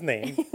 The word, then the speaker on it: Naim: I heard it multiple times in the movie, and I had to stop and be like, Name like N-A-M-E? I'm confused, but no. – Hello, my name is Naim. – Yeah. – What? – It's called N-A-I-M is the way that it is spelled.